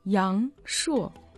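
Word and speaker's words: Яншо (阳朔): Недаром в Китае говорят, что горы и вода в уезде Яншо самые красивые в Гуйлине. – Ну, сначала запомните название этого уезда. Яншо. Ян произносится во втором тоне, а шо в четвертом тоне.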